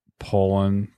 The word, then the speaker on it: Pollan: Yeah, I read Gulp, and then I just started Michael Pollan, P-O-L-L-A-N, Pollan? No, Pollan would be E-N, Pollan.